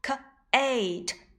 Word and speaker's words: Kate: 原来呀，它们都不发音。 Lake l a k e lake bike b i k e bike rose r o s e rose Kate k a t e Kate 这个 e 呀，我们也叫做哑音 e。